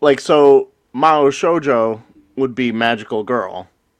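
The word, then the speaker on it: Mao Shoujo (魔法少女): Phonetically would be spelt M-A-H-O space S-H-O-J-O. The way they're saying it in the show, the Japanese pun is M-A-S-U and then shoujo.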